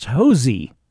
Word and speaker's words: Hosea: Woods' first name is spelled H-O-S-E-A after the 8th century B.C. prophet and nominal primary author of the book named after him in the Hebrew Bible, and is usually pronounced in English as Hosea. But my wife, who hails from Mule Shoe, Texas, assures me that down there, it's pronounced Hosey.